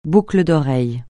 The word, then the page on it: boucle d'oreille: francais